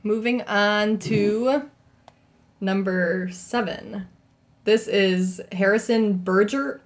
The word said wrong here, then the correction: Bergeron: Bergeron Bergeron.